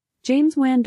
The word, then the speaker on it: James Wan: Did James Wan also do Insidious 2? – I can't remember, honestly. – Alexa, yeah. Who directed Insidious 2? – James Wan directed Insidious. – James Wan.